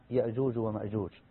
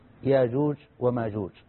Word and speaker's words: يأجوج ومأجوج: وصلنا في حديثنا إلى علامة من علامات الساعة، إنها يأجوج ومأجوج. ويأجوج ومأجوج تنطق بالهمس وتنطق بغير همس، ياجوج وماجوج.